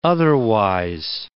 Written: otherwise

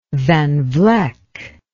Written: Van Vleck

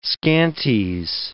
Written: scanties

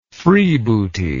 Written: freebooty

